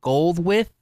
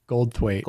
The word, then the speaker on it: Goldthwait: Is that how you pronounce his last name? Goldwith? – Goldthwait.